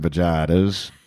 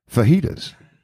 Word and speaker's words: fajitas: And she'll say, Well, sweetheart, I think I'll have an order them vaginas. I said, What? He said, Yeah, she does not know to pronounce it fajitas.